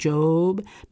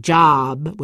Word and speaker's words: Job: Jaime always says how Leonard Braithwaite has got himself the patience of Job. That's pronounced with a long O sound, Job, not like Job with a short ah sound.